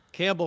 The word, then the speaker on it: Campbell: Campbell, of course, rhymes with gamble, you know.